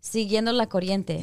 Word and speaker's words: siguiendo la corriente: Le estaba, I'm going to say it wrong. All right. Siguiendo la corriente. – Yeah. Oh, yeah, said it right.